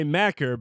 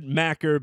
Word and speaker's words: Machir: You can follow him on Twitter at Troy Macker, but you have to spell that TROYMACHIR. So it's said Macker, but it is spelled MACHIR.